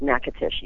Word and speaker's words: Natchitoches: Natchitoches, which is a very strange, it's a very bizarre spelling, but it's pronounced Natchitoches.